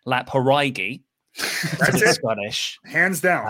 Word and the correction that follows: Laphroaig: well a good start is is pronouncing it Laphroaig.